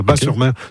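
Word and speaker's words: Batz-sur-Mer: Et surtout, la crêperie s'appelle le Dervin à Bas-sur-Mer, qui font les meilleures crêpes de on prononce bas, le T ne se prononce pas, Bas-sur-Mer.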